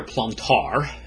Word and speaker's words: Plantard: We're going to be looking primarily at Pierre Plantard. The final D in his name is not pronounced in French.